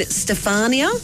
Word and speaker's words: Stefania: But it was Ukraine's Kalush Orchestra singing Stefania. – Yeah. – Is that how it's pronounced? – Yeah, it is.